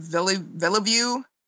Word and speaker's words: Villeneuve: how do you say his last name? Villeneuve? Anyway. – That sounds right.